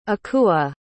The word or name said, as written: akua